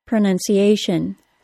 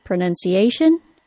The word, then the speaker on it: pronunciation: Words that include T-I-O-N, as well as S-I-O-N, are stressed on the syllable before the T-I-O-N. That means in the word pronunciation, it is the tiny syllable that only contains the letter A that is stressed, pronunciation.